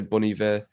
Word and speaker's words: Bon Iver: Well, listen, these people, like, however you pronounce his name, I say Bon Iver. – Yeah, that's right.